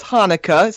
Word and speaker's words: Hanukkah: It is Hanukkah, spelled with an H, actually, though it is pronounced with the CH, yes.